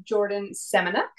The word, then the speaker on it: Jordyn Semeniuk: Today I have Jordyn Semeniuk.